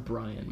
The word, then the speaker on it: Brion: Brian James. – No, look it up. Google it. – I know, but it's pronounced Brian.